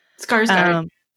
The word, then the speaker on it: Sarsgaard: Sarsgaard.